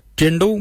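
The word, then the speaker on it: Djendal: Jindal means white. Jindal. Jindal is spelled DJ. E-N-D-A-L, jindal. Then we'll look at jardak. Jardak is grey. Jardak. Jardak is spelled D-J-A-R-D-A-G.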